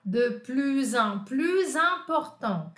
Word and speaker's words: de plus en plus important: Quando o de plus en plus está conectado com um substantivo e esse substantivo é uma vogal, olha ah lá, ó, eu vou pronunciar como nos outros, como eu falei para vocês, né? Então, é de plus en plus important, cada vez mais importante.